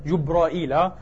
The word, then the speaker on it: جبرائيل: وأحيانا إيه بالهمزة جبرائيل، وأحيانا بغير همزة جبريل جبرائيل، أكثر من 10 وجوه.